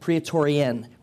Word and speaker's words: Praetorian: Now back in these times, there was an elite special forces in the Roman army that would have been known as the praetori. I'm going to get my pronunciation wrong. I know it. Praetorian with an N at the end.